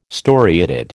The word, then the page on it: storiated: American English